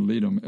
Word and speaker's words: Ledum: But if you have homeopathic leadum, some people pronounce it leadum, L-E-D-U-M, on hand, that is for any kind of puncture, whether it's an insect, whether it is a rusty nail.